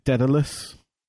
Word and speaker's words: Daedalus: Is it Daedalus? – Yeah.